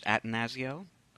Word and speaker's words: Atanasio: I believe he pronounces it Atanasio. – That sounds right.